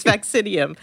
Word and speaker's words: Vaccinium: Sorry, I mispronounced it. It's Vaccinium.